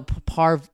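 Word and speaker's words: Pare: Doctor Far. – No, Par. – Par. I never say it right. So Dr. P. Dr. P. Dr. P. Yeah. It's P A R E.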